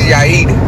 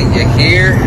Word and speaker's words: Yair: I have this name that white people can't pronounce. I don't know why. But it's called, my name is Yair, but white people always say Yair. Like they always say something. It's not that hard.